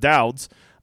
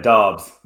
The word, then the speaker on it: Dobbs: I assume that's how you say it. I haven't heard anybody say his name with confidence yet. – I've been saying Dobbs. – Dobbs. Okay, whatever. Now Dobbs, wouldn't that be BB? Whatever.